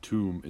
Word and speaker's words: tomb: So it's pronounced like WOOM, and tomb is pronounced TOOM. But bomb is not. It doesn't follow that. – Tomb? – Tomb and womb, but bomb. – Maybe it's not a boom. – That's right, but it's how it's spelled. – No, but the other ones have a double M, right? – No. Womb is spelled W-O-M-B. Tomb is spelled T-O-M-B. And bomb is spelled B-O-M-B. – Okay. – So it doesn't follow the other ones.